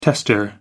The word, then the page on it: tester: American English